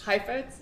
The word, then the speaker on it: Heifetz: Heifetz, yes.